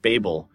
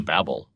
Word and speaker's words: Babel: I grown up pronouncing it Babel. And I'm telling other people, no, it's pronounced Babel. That's how you pronounce the tower. And I actually, somebody looked it up in the dictionary and like, both pronunciations are acceptable in the, I don't know, Oxford English Dictionary. It says both Babel and Babel work.